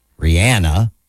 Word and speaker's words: Rihanna: Rihanna, that's how she pronounces it.